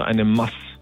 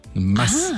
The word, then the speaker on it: Maß: Das ist eher so, die Betonung ist auf dem S, also eine Maß. – Mass. Ah. Ach, kurz.